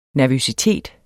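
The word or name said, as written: NERVØSITETS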